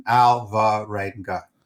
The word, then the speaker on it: Alvarenga: Exactly how it's written. Alvarenga.